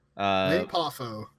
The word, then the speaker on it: Lanny Poffo: Lanny Poffo.